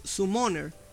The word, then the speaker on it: Summoner: Hay uno que yo uso mucho que es el Summer. El Summer, ¿cómo es? Summer, ¿cómo es? es? Summoner, el invocador.